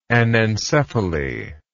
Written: anencephalous

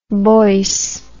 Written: boyce

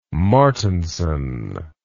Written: martinson

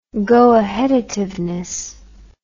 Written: goaheadativeness

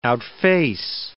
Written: outface

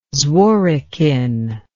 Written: Zworykin

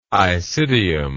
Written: isidium

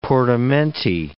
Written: portamenti

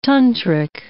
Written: tantric

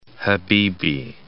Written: Habibie

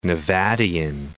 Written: Nevadian

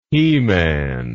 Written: heman

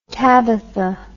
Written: tabitha

